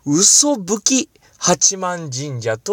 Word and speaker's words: 嘯吹八幡神社: これなんて読むんですかと聞いて、で、その方が、神職の方が教えてくださいました。これは、嘘吹き八幡神社と読みますと。